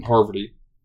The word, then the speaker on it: Parvati: You say her name? – Parvati. Sorry, Freddie. Sorry. – Freddie? – Freddie was the one who called me out. He sent me a phonetic email of how to pronounce her name.